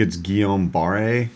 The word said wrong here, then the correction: Guillain: Guillain Barre.